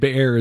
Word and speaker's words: Beer: The Beer is the pronunciation, but it's spelled B-E-E-R.